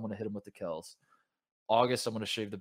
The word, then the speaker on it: Kelce: And I say that because a month ago, you might remember, he just out of nowhere drops the bomb that like his name is not Kelce, it's Kels. – Yeah. – It's pronounced Kels, and so there's like a transformation.